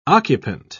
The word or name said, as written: occupant